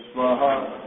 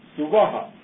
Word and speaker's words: Svaha: I should mention that there is an alternative pronunciation of Svaha. It becomes like Suvaha. And many people actually pronounce that as well. Om Bhur Bhuvah Suvaha. And in fact, in the Taittiriya Upanishad, it is given as Suvaha. Swami Dayananji has mentioned that both are okay.